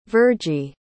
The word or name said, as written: vergie